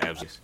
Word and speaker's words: Absecon: Absecon? – That one. – Absecon.